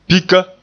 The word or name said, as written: pika